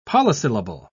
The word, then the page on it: polysyllable: British English